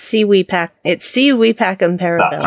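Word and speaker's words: Si vis pacem, para bellum: Civis Pacem Parabellum. – Oh, is it Pacem? Okay, cool. – No, it's Si Wee Pacem Parabellum.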